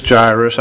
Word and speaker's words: Jairus: And it says Jairus. I wasn't sure about the I or the A to say.